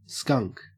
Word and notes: skunk: Australian English

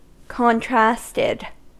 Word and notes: contrasted: American English

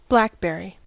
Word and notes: blackberry: American English

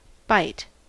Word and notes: byte: American English

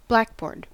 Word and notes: blackboard: American English

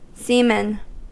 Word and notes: seaman: American English

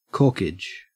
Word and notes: corkage: Australian English

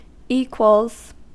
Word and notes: equals: American English

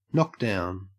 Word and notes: knockdown: Australian English

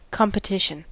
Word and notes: competition: American English

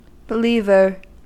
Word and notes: believer: American English

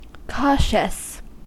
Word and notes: cautious: American English

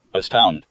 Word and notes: astound: American English

